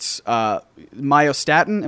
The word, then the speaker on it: myostatin: In a section where you were discussing having the possibility of creating, you know, super musculature in Captain America, you talk about myostatin.